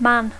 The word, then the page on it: man: English